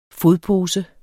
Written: fodpose

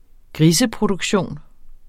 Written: griseproduktion

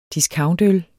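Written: discountøl

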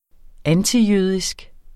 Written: antijødisk

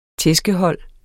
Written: tæskehold

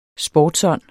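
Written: SPORTSÅND

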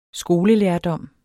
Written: skolelærdom

